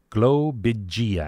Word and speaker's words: globigii: Globigii. I'm going to do the G because it's got the hard G at the beginning.